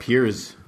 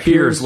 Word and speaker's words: Piers: I like Piers, like a Z. Say it like that. – Piers, like multiple Piers.